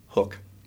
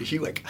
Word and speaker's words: Hook: So the UE is the German, and the pronunciation is hook. – Ah. – So I was pronouncing it Hewick. So. – I think I did the same thing at one point. – So nope, it's hook.